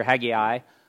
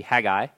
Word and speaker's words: Haggai: There's an English pronunciation, which you might hear Haggai, so three syllables. The Hebrew pronunciation would just be Haggai, so a two-syllable word here.